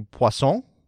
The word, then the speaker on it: poisson: Now, if because it's French, I guess it'd be poisson. Is that how you say it? Jared, you're French. Dubois? Poisson? – Sure. It sounds a lot like croissant, so be sommelier? – Careful.